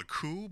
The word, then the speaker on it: coup: But speaking of things that are not good, baby, there was a coup, and it's not, apparently it's not called a coup, it's called a coup, but there's a silent P in there.